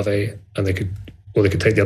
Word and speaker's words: Aave: AAVE.